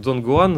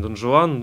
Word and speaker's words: Дон Гуан: да, ну а, то есть на звук, конечно, мы произносим его Дон, Дон Гуан, называем Дон Жуан, ну, как-то вот так все время. То, то Дон Жуан, то Дон Гуан.